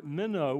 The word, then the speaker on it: meno: but the Greek word here is the word meno. We spell it in English, M-E-N-O, with a long O in the end.